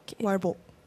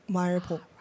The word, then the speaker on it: 말복: Oh, 말복. Sorry. – Can you say that one more time? – 초복, 중복, 말복. – Okay, 초복. Oh, correct. – Right, of course. – I mispronounced it the first time.